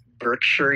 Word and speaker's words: Berkshire: Berkshire, yeah.